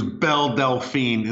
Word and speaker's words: Belle Delphine: I thought I was going to introduce Belle Delphine is how you say her name, sir.